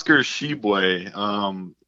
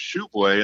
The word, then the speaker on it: Tshiebwe: When he was a recruit, he was a highly touted recruit coming out of high school, and a lot of us thought it was Tshiebwe, and that's actually what West Virginia put in its pronunciation guide when he first got there. But they since changed that to Tshiebwe, and that's how you say it.